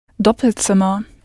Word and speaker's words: Doppelzimmer: You had to change the vowel sound slightly, double to doppel, and I hope you pronounced the Z as though it was a T-S, Z, Doppelzimmer.